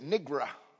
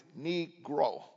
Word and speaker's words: Negro: And she didn't say Negro. She said Nigra. That was the way she pronounced it, Nigra. And of course, smart me stood up one day and I took it up to her. I said, the word is Negro.